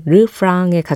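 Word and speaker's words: Refrain: 어, 이 노래 제목을 제가 영어식으로 리프레인이라고 읽었는데 이 노래에서는 르프랑에 가깝게 발음하죠. 네, 르프랑으로 소개를 다시 해드릴게요.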